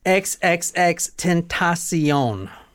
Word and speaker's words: XXXTentacion: Xxxtentacion. So let me pronounce it for you. Xxxten, ten, ta, T-A-H, C, like I see you, S-E-E, own, like Oprah's network. Xxxtentacion.